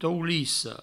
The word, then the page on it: toulissa: toulisso